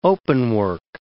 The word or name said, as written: openwork